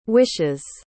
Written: wishes

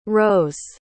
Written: rhos

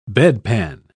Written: bedpan

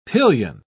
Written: pillion

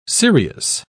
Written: cereus

